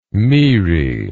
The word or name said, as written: Moerae